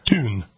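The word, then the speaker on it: Thun: Texter says Greta Thunberg doesn't pronounce the TH in her name as TH. It is pronounced Thunberg. – Tune.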